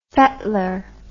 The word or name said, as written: fettler